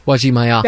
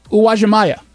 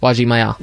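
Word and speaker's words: Uwajimaya: Wajimaya. – That one. I have been saying it wrong my entire life. I was always saying Wajimaya. What is it? – Wajimaya.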